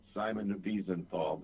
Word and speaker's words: Wiesenthal: I have chosen to pronounce it Simon Wiesenthal because he has an Austrian accent.